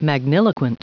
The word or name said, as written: magniloquent